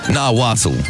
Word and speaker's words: Nahuatl: In its original language, let's see if I can pronounce that. Nahuatl. – Nahuatl pronunciation. Come on. – Nahuatl. Yeah, cool. It's not spelt like that, trust me.